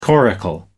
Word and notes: coracle: American English

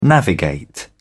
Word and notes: navigate: British English

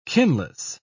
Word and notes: kinless: British English

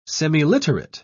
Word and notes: semiliterate: British English

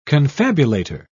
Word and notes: confabulator: British English

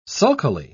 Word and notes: sulkily: British English

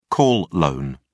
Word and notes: call loan: British English

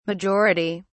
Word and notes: majority: British English

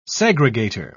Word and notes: segregator: British English

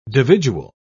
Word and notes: dividual: British English

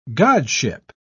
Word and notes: godship: British English

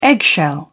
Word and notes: eggshell: British English

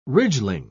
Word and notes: ridgeling: British English

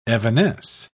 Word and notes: evanesce: British English

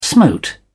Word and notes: smote: British English

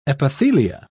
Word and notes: epithelia: British English

